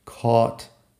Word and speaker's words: caught: Fíjate que suena igual aparte de la primera letra ahí. Lo que pasa es que caught se escribe con C-A-U-G-H-T mientras que bought se escribe con B-O-U-G-H-T. En todo caso, la pronunciación de la vocal es igual en las dos.